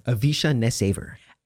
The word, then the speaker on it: Avisha NessAiver: Avisha NessAiver.